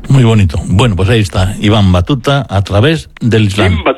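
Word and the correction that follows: Ibn Battuta: Hay que pronunciar bien. Im Batuta.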